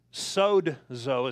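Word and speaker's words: sozo (σῴζω): It literally is the word sozo, is how it's pronounced, even though it's S O Z O.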